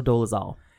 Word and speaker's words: Dolezal: Rachel Dolezal.